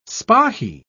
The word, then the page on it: spahee: British English